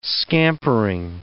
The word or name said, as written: scampering